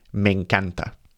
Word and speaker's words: me encanta: And don't forget the e sounds kind of join there, so it's typically pronounced me encanta.